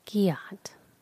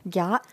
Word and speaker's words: gyatt: Giat. I'm sorry. I'm saying it wrong. It's giat. – Giat? – Giat. – Giat. – G-Y-A-T. – Like, there's giat? Actually two T's is what I was told. So G-Y-A-T-T.